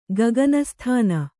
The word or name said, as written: ಗಗನಸ್ಥಾನ